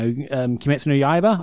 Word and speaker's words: Kimetsu no Yaiba: Like, the most recent thing I've been reading has been Demon Slayer, you know, Kimetsu no Yaiba, I think that's how you pronounce it.